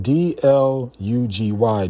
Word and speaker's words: Dlugy: It's spelled D L U G Y, Dlugy, And I would assume by that name, he may be Russian.